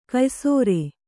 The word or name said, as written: ಕೆಯ್ಸೇರ್